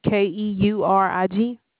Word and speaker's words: Keurig: I don't know how to pronounce it. Let me see how it looks. It's spelled K. The spelling of it is let me see, K E U R I G.